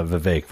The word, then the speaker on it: Vivek: I I have these thoughts on on uh, Vivek rhymes with cake.